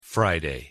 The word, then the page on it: friday: English